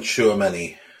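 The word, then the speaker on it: Tchouaméni: Tchouameni, is that right? – Aurelien Tchouameni. – Tchouameni, there you go.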